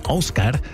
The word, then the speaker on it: Ásgeir: Der Mann kommt aus Island, deswegen wird er auch so ausgesprochen. Ásgeir.